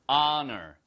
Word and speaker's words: honor: honor. 아시겠죠? 그래서 honor인데 시작이요. honor가 아 발음을 시작하잖아요. 그러니까 what a 아 너, 이 발음이 힘들어요.